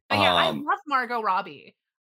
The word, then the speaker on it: Robbie: I always want to say Margot Robbie because her name is Margot, and then I want to pronounce her last name French, but she's Australian as fuck, and it's Robbie.